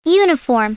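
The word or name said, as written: uniform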